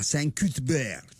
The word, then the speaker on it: Saint-Cuthbert: La 40 a Saint-Cuthbert. – Come l'hai detto bene, con una pronuncia così nativa.